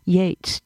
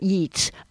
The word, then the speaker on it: Yeats: And I also had an older sister who loved poetry, and so she would say, uh, "It's pronounced Yates, not Yeats,"